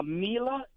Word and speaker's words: Mila: Was it Mia, then Charlotte? Did I get the names right? You just hanging with the little girls? You you got it, Martin. Yeah, Mila, that's spelled M I L A.